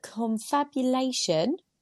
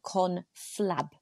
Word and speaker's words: confabulation: Confab is an informal private conversation or discussion, but confabulation is not that. – Tell us what it is. – So, right, girls, I think I've been pronouncing it wrong. Confabulation, whereas you're saying conflab.